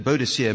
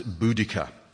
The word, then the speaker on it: Boudica: She's been known as Boadicea, but a group of Cambridge linguists recently insisted from a study of proto-Celtic languages that Boadicea must be pronounced Boudica.